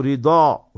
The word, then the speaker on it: Ridha: So anyway, if you keep the name Rida, is with a dod. So R-I-D-H-A. So Rida. You must pronounce it correctly also.